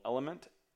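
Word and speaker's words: LMNT: I particularly like a brand right now called Element, which is spelled L-M-N-T. I also use a brand called Ultima. I've used salt sticks, I've used Nuun's, N-U-U-N.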